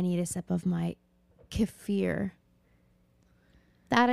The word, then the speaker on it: kefir: And it is pronounced kefir because I was like,